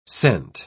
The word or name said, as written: sent